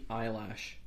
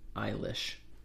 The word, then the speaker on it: Eilish: Billy Eyelash. Yes. did you actually? did you? Wow, that's a little bit weird. How are you supposed to pronounce her name? Eilish. Eilish. Okay,